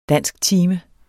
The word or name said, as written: dansktime